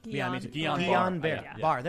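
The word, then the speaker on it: Guillain-Barré: I will also say, however, to Charlie, Guillain-Barre syndrome, which I'm probably pronouncing wrong, it's a side effect. – I always mispronounce it. – Guillain-Barre. There you go.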